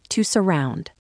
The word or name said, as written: to surround